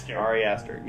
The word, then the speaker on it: Ari Aster: Ari Aster.